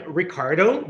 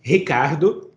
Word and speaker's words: Ricardo: So I'm Ricardo. That's how I pronounce my name in English. And Ricardo, that's how I pronounce my name in my first language, which is Portuguese.